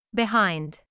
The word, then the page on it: behind: bɪˈhaɪnd